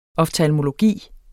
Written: oftalmologi

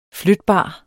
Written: flytbar

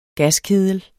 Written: gaskedel

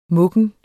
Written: muggen

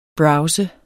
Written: browser

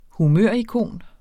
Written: humørikon

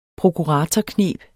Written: prokuratorkneb

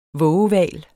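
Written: vågehval

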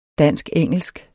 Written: dansk-engelsk

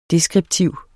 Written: deskriptiv

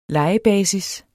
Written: lejebasis

